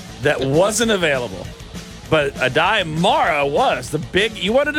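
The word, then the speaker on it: Aday Mara: You know, I'll just go with the commissioner's pronunciation. Adai Mara. Sounds good to me.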